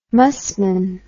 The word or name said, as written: musmon